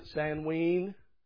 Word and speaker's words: Samhain: Some would pronounce it Samween.